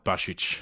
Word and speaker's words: Basic: You got to pronounce it correctly, though. It's pronounced Bashich.